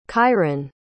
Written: kyron

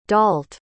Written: dault

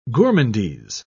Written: gourmandise

